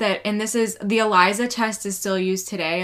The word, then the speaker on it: ELISA: So in the medical world, it's pronounced ELISA, but it's spelt like Elisa spelt her name.